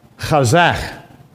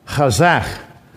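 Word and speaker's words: chazach (חזק): That H there with the dot, you pronounce this. So it's chazach. Chazach. That's how you say it.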